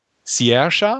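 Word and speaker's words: Saoirse: Es geht um Hannah, ähm, gespielt von, oh, lass mich auf meinen Zettel gucken, Sierra habe ich es richtig ausgesprochen? – Perfekt.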